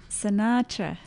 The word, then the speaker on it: Sinatra: Oh, how do you say it? – Sinatra. – Sinatra.